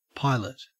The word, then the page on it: pilot: Australian English